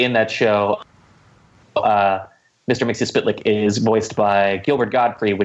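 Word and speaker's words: Mxyzptlk: Yeah, Mr. Mixius Spitlick. At least that's how they pronounced it in the Superman cartoon, which, funny enough, I did enjoy.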